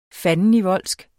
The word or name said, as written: fandenivoldsk